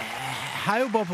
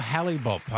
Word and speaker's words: Hale-Bopp: Yes, that is right. Yeah, Hale-Bopp or Halley-Bopp. I think it was called Halley-Bopp, but you hear it pronounced different ways.